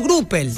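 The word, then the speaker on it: graupel: El Gruppel se escribe G-R-O-U-P-E-L. Gruppel o Gruppel, quería decir como vos quieras. Bueno, sí, vos sabés que, escúchame, el sábado digo, cayó Gruppel en un lado.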